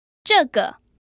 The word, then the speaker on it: 这个: 这个. – So that's a fourth tone in the neutral tone.